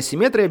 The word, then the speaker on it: симметрия: Это просто вращательная симметрия без всяких условностей. Есть еще трансляционная симметрия, кстати, если временами у меня будет проскакивать ударение симметрия, ну, не надо меня по голове бить словарями. Ударение на «и» тоже возможно.